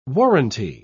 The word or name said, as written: warranty